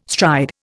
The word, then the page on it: stride: Indian